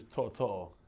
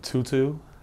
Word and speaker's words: To'oTo'o: I've heard, so how you pronounce it is Toto. I've heard Tutu. I've heard Tao Tao.